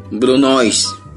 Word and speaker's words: brunoise: Brunoise, o también se ha escuchado así como se escribe, Brunoise.